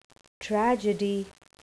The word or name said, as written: tragedy